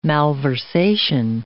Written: malversation

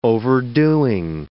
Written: overdoing

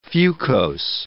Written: fucose

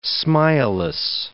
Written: smileless

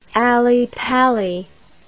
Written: allypally

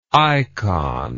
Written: eikon

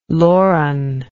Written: Loren

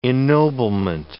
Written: ennoblement

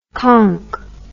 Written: konk

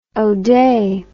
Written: oday

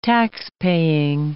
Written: taxpaying

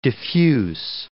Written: diffuse

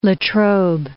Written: Latrobe

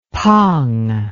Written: pong

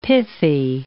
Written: pithy

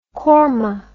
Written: qorma

